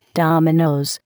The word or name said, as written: dominoes